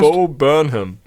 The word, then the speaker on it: Bo Burnham: Bo Burnham.